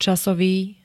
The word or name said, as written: casovy